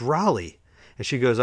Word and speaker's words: Raleigh: So it's Raleigh, and it's Raleigh, R-A-L-E-I-G-H, which is pronounced Raleigh, not Rayleigh, not Riley, like everybody says.